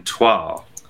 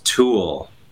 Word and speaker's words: toile: So I was wearing toile, and this woman that was seated at our table kept saying, no, it's pronounced tulle. And I was like, no, it's not.